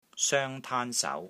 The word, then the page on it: SOENG1 TAAN1 SAU2: Cantonese